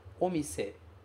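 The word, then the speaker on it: omise (お店): Aí você vai pronunciar omise. Omise.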